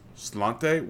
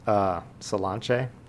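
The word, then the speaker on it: Sláinte: Slante, what is that? – So I think it's pronounced Solanche. It's like Gaelic for cheers.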